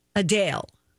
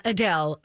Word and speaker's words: Adele: That her name is actually Adele. Not Adele, Adele.